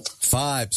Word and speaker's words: Fybe: Am I saying this right? Fiber? – Five. – Sorry. – So I believe. – Yeah. – Yes. F-Y-B-E. – F-Y-B-E.